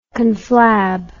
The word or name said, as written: conflab